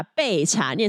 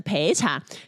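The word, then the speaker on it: 焙茶: 接下来有一个网友他就说，这几个新资料夹，彩玲跟呱吉一直把备茶念成陪茶，其实呢，我自己根本也不在乎读音，反正听得懂就好。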